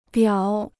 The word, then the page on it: 表: biǎo